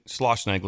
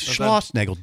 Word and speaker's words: Schlossnagel: He mentioned Schlossnagel. Did I say it right? – Yes, Schlossnagel, Jim.